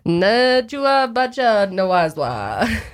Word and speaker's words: Nebuchadnezzar: Nebuchadnezzar. No way, that's right. – Olajuwon. – Okay, but yeah, he used to tell people he wanted to be like eaten like a cow. – Nebuchadnezzar, dude.